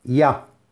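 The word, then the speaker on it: Ya (יה): Son las letras o palabras Yud hey que se pronuncia Ya, y que es la primera parte del tetragramatrón.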